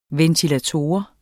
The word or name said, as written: VENTILATORER